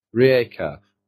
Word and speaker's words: Rijeka: Rijeka? – Rijeka.